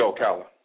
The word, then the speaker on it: Ocala: And I got to Florida, we were driving from Georgia to Miami, and we passed through Ocala. Am I pronouncing it right? – Yeah, Ocala.